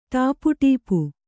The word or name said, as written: ಟಾಪುಟೀಪು